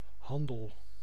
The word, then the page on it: trade: -